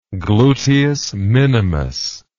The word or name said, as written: gluteus minimus